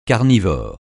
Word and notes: carnivore: francais

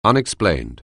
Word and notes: unexplained: anglais

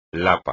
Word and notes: lapa: espagnol

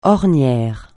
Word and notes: ornière: francais

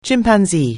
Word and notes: chimpanzee: anglais

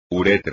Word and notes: uretra: espagnol

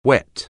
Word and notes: whet: anglais

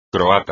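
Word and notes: croata: español

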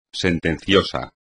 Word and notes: sentencioso: espagnol

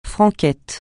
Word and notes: franquette: francais

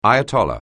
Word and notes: ayatollah: anglais

